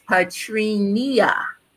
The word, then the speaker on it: Patrina: It's my mother put an extra syllable in my name, so it's Patrina.